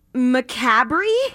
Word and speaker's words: macabre: So the R is silent? – Yeah, macabre.